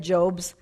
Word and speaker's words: Job: I always thought it was interesting that Job's name is pronounced Job, but obviously it looks like what? Job, right?